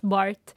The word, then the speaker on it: Barthes: jag tror det uttalas Bart.